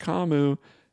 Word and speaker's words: Kamu: It's also it is pronounced Kamu. There was a reporter who asked him about Kamu, and Brian Burns was like, "Oh, who? Oh, you mean Kamu?" So I'm pronouncing his name correctly now.